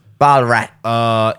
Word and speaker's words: Ballarat: Did I say that right? Ballarat? – Damn. – Yeah. – Ballarat.